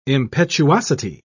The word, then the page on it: impetuosity: British English